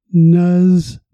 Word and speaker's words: Nussbaum: Break it into two syllables and say it softly. Nussbaum.